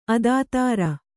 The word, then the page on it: ಅದಾತೃ: adātř